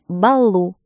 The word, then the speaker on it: Балу: Фамилия моей тетки Балу с ударением на У, поэтому она назвала свой салон Балу на углу.